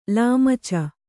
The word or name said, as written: ಲಾಮಚ